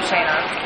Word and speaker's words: Shana: And I'm Shana. – How do you spell it? – S-H-A-N-A. – Say it again. – S-H-A-N-A.